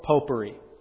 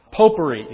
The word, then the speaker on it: popery: Rutherford writes this, Samuel Rutherford, quote, "Is not this the basis and mother principle of popery?" There he's not speaking of sweet-smelling aroma that you might put in your house, but popery, you have to enunciate, focus on that first syllable.